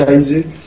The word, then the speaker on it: Heinze: Dans les grandes lignes, c'était bah, concernant le petit dossier Heinze, et donc à prononcer Heinze et pas Heinze.